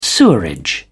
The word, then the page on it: sewerage: British English